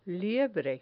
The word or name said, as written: liebre